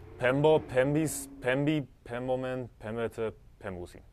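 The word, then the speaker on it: pembo (πέμπω): So, yeah, so basically, the pronunciation on this one with the modern pronunciation, the MP actually can either make a MB sound. I said pempo, but really it's like you said, pembo is better. But basically, sometimes they just do it as just a B too, just pebo.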